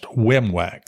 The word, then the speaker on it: WIMWAC: That's an Army acronym pronounced WIMWAC. That's the first letter of each one of those words.